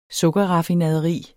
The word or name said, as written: Sukkerraffinaderi